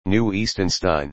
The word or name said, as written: New Eastonstein